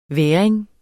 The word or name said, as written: væring